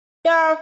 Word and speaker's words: Doerr: Không có ý chơi chữ, Doerr phát âm rất giống door là cánh cửa.